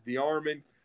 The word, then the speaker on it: DeArmond: Well, yeah, it's you're very, very close. It's DeArmond. But it's got kind of a French origin in it.